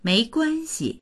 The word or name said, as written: mei2guan1xi